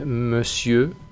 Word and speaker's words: monsieur: En cuanto a vocabulario, también aparecen algunas palabras como señor, que se dice monsieur. Esta palabra se escapa a todas las reglas de la pronunciación. No intentéis buscar una lógica, simplemente aprendedla tal cual.